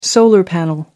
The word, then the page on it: solar panel: American English